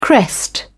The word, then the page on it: crest: British English